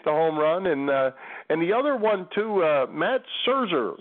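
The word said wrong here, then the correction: Szczur: Not Serger. That's not the right way to pronounce his name. The Villanova guy from Cape May that was the running back who made the Chicago Cubs, and he it's Caesar, Matt Caesar.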